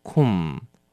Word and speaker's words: comb: comb 是梳子那么小心了它的 b 是不发音的你只念成 comb